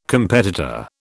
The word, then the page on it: competitor: British English